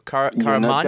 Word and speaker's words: Caramagna: I just realized I wanted to pronounce the G, but I'm not sure if I'm supposed to. Caramagna? – You're definitely not supposed to.